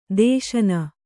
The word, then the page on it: ದೇಶನ: Kannada